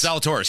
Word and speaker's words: Zalatoris: Okay, so there was this quote that I wanted to share with you, and how do you say his name? Will Zalatoris. – Yes, Zalatoris. Yes.